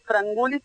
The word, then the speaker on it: Frangoulis: Sí, mi apellido es Frangulis, se escribe Frangoulis. – Claro, por eso uno tiende a pasar la O a U.